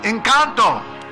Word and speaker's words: Encanto: I need to make sure I say the name right. Encanto? Encanto. Encanto. See, I knew she'd straighten me out.